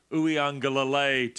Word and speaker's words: Uiagalelei: Just seems to me that this is going to end up in the hands of Ian Book and DJ Uiagalelei to win the game.